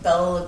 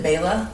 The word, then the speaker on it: Bela: Is it Bela? Mm-hmm. I thought they said Bella. – We say it, but I'm pretty sure it's Bela is how they pronounce it, actually.